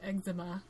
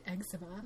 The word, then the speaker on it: eczema: We don't say eczema. – No. – We would say eczema. – Yeah. Like whatever, dry skin or whatever it is. So it's really like, more like American versus British pronunciation.